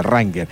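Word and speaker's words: Wrangler: La W no se pronuncia, Ranger.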